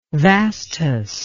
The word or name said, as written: vastus